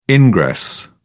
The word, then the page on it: ingress: British English